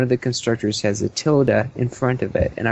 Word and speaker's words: tilde: I was trying to figure out what the tittle meant. Is that how you say it? Tittle, title, or how is it pronounced? – Tilde. – Tilde, there you go.